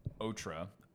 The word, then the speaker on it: Ottara: It's going to be Ottara, O-T-T-A-R-A. Middle name is Sacha, S-A-C-C-A. Last name is Pyne, P-Y-N-E.